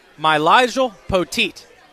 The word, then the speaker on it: Mylyjael Poteat: Men's basketball today, and joining me right now from Virginia Tech, Mylyjael Poteat.